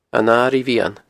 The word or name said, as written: An Airigh Mheadhan